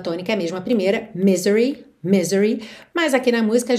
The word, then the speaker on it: misery: Então o I desse Y é alongado, acaba ganhando aí uma força extra, mas normalmente sílaba tônica é a primeira, tá bem? Misery.